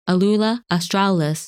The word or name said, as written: Alula Australis